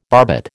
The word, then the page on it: barbet: American English